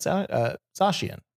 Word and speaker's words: Zacian: Zacian is the actual pronunciation. Talking about Zacian, all of the reasons why it was a lot.